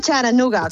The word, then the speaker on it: Chattanooga: Se dice Charanooga, con la T como muy suave, sí.